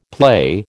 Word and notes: play: American English

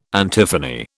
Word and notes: antiphony: British English

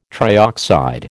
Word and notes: trioxide: American English

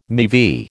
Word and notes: MeV: American English

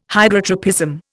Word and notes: Hydrotropism: Indian

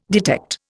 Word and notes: detect: Indian

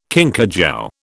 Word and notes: kinkajou: British English

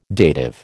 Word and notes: dative: American English

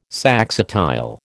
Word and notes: saxatile: American English

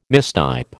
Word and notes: mistype: American English

